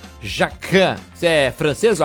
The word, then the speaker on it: Jacquin: Deixa eu só retificar aqui que já a Daniela me passou aqui, é Jacquin, não Jacquin, né? O mestre-chefe lá, né? Então é Jacquin. Jacquin. É que se escreve Jacquin, né? J-A-C-Q-I-U-I-N. Mas é mestre, né?